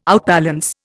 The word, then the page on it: outbalance: Indian